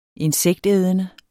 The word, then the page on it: insektædende: [ -ˌεːðənə ]; [ -ˌεˀðənə ]